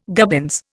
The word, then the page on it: gubbins: Indian